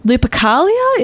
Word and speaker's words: Lupercalia: But others claim that the Christian Church decided on this date in an effort to Christianize the pagan celebrations of Lupercalia. Is that how it would be pronounced? – I would pronounce it that way.